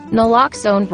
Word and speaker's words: naloxone: For the record, it's naloxone. Nuh, like, is naloxone addictive? Nuh-uh. Lox, like naloxone keeps humans safe, like locks keep bikes safe. And own, like naloxone can save lives in our very own communities.